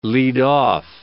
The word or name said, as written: lead off